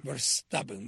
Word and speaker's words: Verstappen: Para sorpresa de nadie, el piloto holandés Max Verstappen ganó la quinta rama del Campeonato Mundial de Fórmula 1. Max Verstappen. – Verstappen, Max Verstappen.